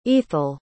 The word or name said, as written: eathel